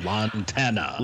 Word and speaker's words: Lantana: Lantania. – I'm probably totally mispronouncing that. – Lantana. – Lantana. Yeah, Lantana, I think, is probably closer to it.